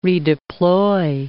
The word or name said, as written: redeploy